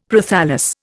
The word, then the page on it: Prothallus: Indian